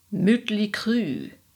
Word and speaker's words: Mötley Crüe: Looking at you, Mötley Crüe, correctly pronounced, you sound like a toy French car from the 40s. Mötley Crüe.